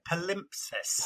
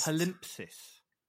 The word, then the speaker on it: palimpsest: Palimpsest. – Palimpsest. – Palimpsest. It ends in a T. – Oh, palimpsest. Okay. – Palimpsest. Yeah. P-A-L-M-I. Sorry, hang on. P-A-L-I-M-P-S-E-S-T.